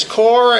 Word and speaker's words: Corps: The elders of the church, first of all, his company, then his core, spelled both ways, core and corpse, as we might say, but it's pronounced core, like the Marine Corps.